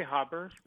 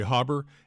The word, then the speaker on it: Haber: Fritz Haber, is it Haber or Haber? – I say Haber, but okay. I've heard it pronounced both ways. – Okay, Haber.